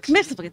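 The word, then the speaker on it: Mxyzptlk: Mixplitik, thank you.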